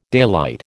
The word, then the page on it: daylight: American English